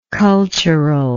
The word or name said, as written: culturally